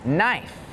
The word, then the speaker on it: knife: Some examples with a silent K are the words knee, night, and knife.